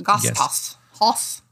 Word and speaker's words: Gasthaus: G-A-S-T-H-A-U-S, Gath House. – Gas House.